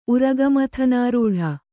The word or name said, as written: ಉರಗಮಥನಾರೂಢ